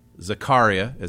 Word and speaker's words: Zakaria: This clip, that's Fareed, Zakaria is how you pronounce his last name.